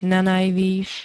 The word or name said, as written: nanajvys